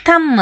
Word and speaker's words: ಥಮ್: ನಾವು ಕನ್ನಡದವರು ಹೆಚ್ಚಾಗಿ C O M ಬಿನ ಕೋಂಬ್ ಅಂತ, ಎಲ್ ಎ ಎಂ ಬಿನ ಲ್ಯಾಂಬ್ ಅಂತ, T H U M ಬಿನ ತಂಬ್ ಅಂತ ಉಚ್ಚಾರ ಮಾಡ್ತೀವಿ. ಆದರೆ ಇಲ್ಲಿ ಎಲ್ಲ ಬಿಗಳು ಸೈಲೆಂಟ್ ಆಗಬೇಕು. ಸಿ ಒ ಎಂ ಬಿಗೆ ಕೋಮ್ ಅಂತ ಹೇಳಬೇಕು, ಎಲ್ ಎ ಎಂ ಬಿಗೆ ಲ್ಯಾಮ್, ಟಿ ಎಚ್ ಯು ಎಂ ಬಿಗೆ ಥಮ್ ಅಂತ ಹೇಳಬೇಕು.